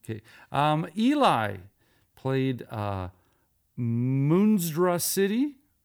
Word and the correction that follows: Monstrosity: I hope I said that properly. M- Monstrosity, there we go.